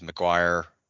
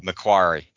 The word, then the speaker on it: Macquarie: I think it was Macquarie. I'm probably saying that incorrectly. – Macquarie Capital. – Macquarie, thank you.